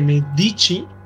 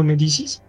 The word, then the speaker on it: Medici: Pues mira, como tú dijiste, Catalina de Medici, creo que se me pronuncia así, no Medicis.